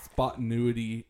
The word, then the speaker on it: spontaneity: so she was like, uh, you said that wrong. It's spontaneity. And I'm like, tomato, tomato.